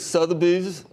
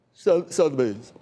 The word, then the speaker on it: Sotheby's: It's Sotheby's. Is that a good pronouncer for you? – I wouldn't expect any different from you. – Sotheby's.